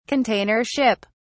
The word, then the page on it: container ship: British English